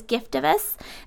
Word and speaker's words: Giftovus: And you guys, what we're talking about is Giftovus.